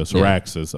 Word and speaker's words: Caraxes: It's Caraxes. – I think is how they say it in the show. Caraxes.